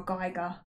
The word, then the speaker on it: Geiger: And according to a Geiger counter, is it Geiger or Geiger? – It's Geiger.